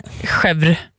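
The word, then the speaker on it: chèvre: Chevre, säger man. Det är ju inget e i slutet där.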